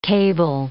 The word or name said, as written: cable